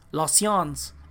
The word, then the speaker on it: science: Entonces, es science, en inglés lo pronuncia science, pero en francés es la, science.